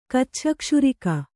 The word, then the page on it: ಕಚ್ಛಕ್ಷುರಿಕ: Kannada